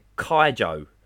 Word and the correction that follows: kaiju: Uh, kai, yeah, kaiju. – kaiju. That kaiju,